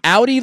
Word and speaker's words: Audi: Audi, like the car, you spell it A-U-D-I, Crooks is her last name, so it's a great name.